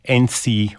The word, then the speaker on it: Ence: I'm just going to call it NC Restaurant. It is spelt E-N-C-E. Actually, I've seen multiple spellings for it, but it is definitely NC Restaurant.